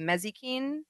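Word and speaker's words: Mazikeen: Mezikeen? Is that how you pronounce it? – Yeah.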